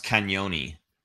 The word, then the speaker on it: Cagnoni: I so, want to say that's pronounced Cagnoni. Cagnoni? Yeah. Is that the Italian? I know there's a G there, but I'm pretty sure.